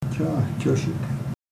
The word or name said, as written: chahchesek